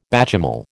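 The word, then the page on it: béchamel: American English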